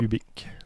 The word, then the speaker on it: Hubic: Et ça s'écrit H-U-B-I-C. – Oui, c'est ça. – Ubiq.